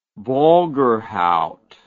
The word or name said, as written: Borgerhout